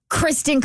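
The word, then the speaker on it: Kristin: Dear Kristen Klingshern.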